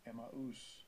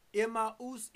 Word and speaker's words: Emmaus: Strong's G, 1695, Emmaus. – Emmaus in the Greek.